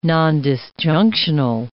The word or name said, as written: nondisjunctional